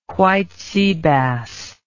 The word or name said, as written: white sea bass